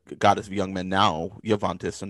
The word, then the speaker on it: Juventus: Okay, we're going to check in with Javantis. – Okay, and pronunciation is Juventus.